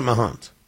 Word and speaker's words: Mahant: I think I pronounce it Mahant. M-A-H-A-N-T.